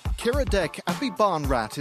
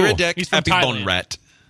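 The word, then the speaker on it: Kiradech Aphibarnrat: I think I've got a pronunciation. – Okay. – Kiradek Abibonrat is one of the most... – Abibonrat. – Abibonrat. That sounds cool. – Kiradek Abibonrat. – Okay. That sounds a lot better than what I was trying to say.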